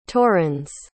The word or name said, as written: torrens